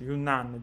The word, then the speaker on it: Yunnan: Eh, spero di aver pronunciato bene perché eh, io non lo so, Liu, se, se vuoi, come si dice, Yunnan, giusto? Liu, mi confermi la pronuncia. Okay.